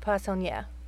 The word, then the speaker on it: Poissonnière: slowly guiding the Protoss down the Boulevard Poissonnière.